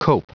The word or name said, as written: cope